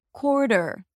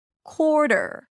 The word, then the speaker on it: quarter: The second syllable is basically a T, schwa, R, ter, ter. But since this T is between an R vowel and a vowel, it's a flap T. So it sounds more like a D than a T. Quarter. Der, der. Do you hear that? Quarter, der.